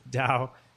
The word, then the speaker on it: Tao: TAO is an approximation of how it would be pronounced. And um, to the best of my knowledge, Dao is, uh, you know, a word of Chinese origin and kind of,